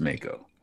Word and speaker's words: Mako: Princess Mako, yes.